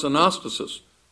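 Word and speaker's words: anastasis (ἀνάστασις): In fact, if you look at the Greek word um, resurrection in Greek pronounced anastasis, almost all of the time it does refer to a physical resurrection.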